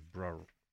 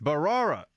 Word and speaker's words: Bharara: I thought it was Brar. I always get this. Is this guy's name? Am I getting his name wrong here? Bharara. It's Bharara.